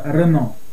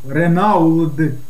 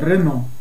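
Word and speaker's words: Renault: Да, французский язык – это особенный язык, потому что здесь на первое слово весь мир знает, как пишется «Рено», да? – Да. – Вы знаете, сколько там букв? «Ренауд», да? – Ну. – А читается «Рено».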